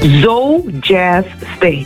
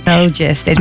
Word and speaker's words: Zo Jazz Stage: Hoe spreek je het zelf uit? Zuidoost Jazz Stage of Zo Jazz Stage? – Zo Jazz Stage. – Zo Jazz Stage. Maar Zo staat voor Zuidoost natuurlijk, hè? – Ja, klopt.